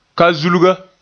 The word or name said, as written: kazulga